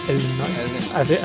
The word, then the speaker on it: Illinois: A Chicago, Illinois. Así se pronuncia.